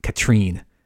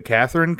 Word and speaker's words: Katrine: While working out how to steal Duvall's one-of-a-kind black pearl, Red begins an affair with Duvall's much younger wife, Katrine. I think it's listed as Catherine, but everyone pronounced it Katrine. – Catherine because that's how the character name is spelled in the credits.